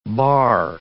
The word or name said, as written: barre